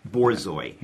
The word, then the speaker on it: Borzoi: Borzoi. – Yeah. – So there's a chapter, I think it's called, of Dish Rags and Borzoi. – Yeah. – And you talk about seeing a Borzoi in Washington Square Park.